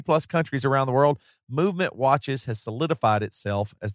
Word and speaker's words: MVMT: Movement Watches, MVMT, pronounced Movement, was founded on the belief that style shouldn't break the bank.